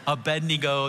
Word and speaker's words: Abednego: It's the story that God gives Abednego. That's how you say it. A little Southern sneaks in.